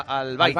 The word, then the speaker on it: Al-Bayt: El estadio se llama Al-Bayt. – Al-Bayt. – Al-Bayt. – Al-Bayt de Al-Jor. – Sí, la Jaima. – Sería la pronunciación.